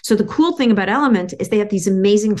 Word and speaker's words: LMNT: And element is spelled L-M-N-T. There's no E's there.